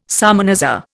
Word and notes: salmanazar: Indian

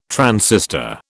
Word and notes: transistor: British English

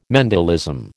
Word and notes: Mendelism: American English

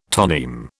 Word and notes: toneme: British English

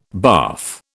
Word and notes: bath: British English

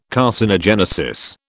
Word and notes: carcinogenesis: British English

